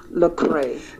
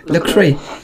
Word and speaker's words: Lecrae: I did a little research on it, and the name of the art, I've never actually heard of the artist, Lecrae. Is that how you pronounce it? – Yeah, Lecrae. – Lecrae.